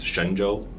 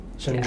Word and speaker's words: Shenzhou: And the spelling I've seen means is actually pronounced Shenzhou. – Shenzhou, yeah.